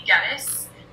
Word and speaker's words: Geddes: So my name is Katie Geddes. I tell the kids it rhymes with lettuce, but most of them call me Miss G.